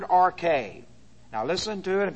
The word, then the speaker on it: arche: In arche, and this is the preposition in, and you have to deal with an understanding of prepositions, A-R-C-H, long E, in arche.